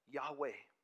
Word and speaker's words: Yahweh: I am is pronounced Yahweh.